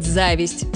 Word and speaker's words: зависть: Все это замечательно, но может вызвать зависть с ударением на первом слоге.